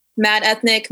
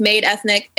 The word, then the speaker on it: Made Ethnic: Mad Ethnic, Made Ethnic. It works both ways. Okay. It's the same word, really.